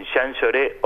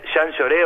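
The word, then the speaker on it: Jean Jaurès: Es un teatro que está en Jean Lloré 858. Y hablando de pronunciaciones, Jean Lloré o Jean Jaures, y como ustedes quieran. – Jean Lloré es más fácil. – Ya aprendimos a decir Jean Lloré.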